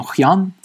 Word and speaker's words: Hyun: Есть еще Хьюнс Доджо, оно Хьян, типа, ну пишется Хьюн, H-Y-U-N.